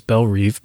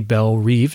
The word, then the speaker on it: Belle Reve: And by the way, we know it's Belle Reve because the guard's arm patch says so. And I tend to say Belle Reve, not Belle Rev, because that's how it's said in A Streetcar Named Desire.